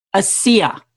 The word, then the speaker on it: ASEA: You're an independent consultant right now for, and excuse me if I mispronounce this, is it pronounced ASI? – It's pronounced ASEA. – ASEA.